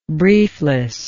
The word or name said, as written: brieflessness